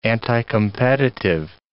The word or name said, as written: anticompetitive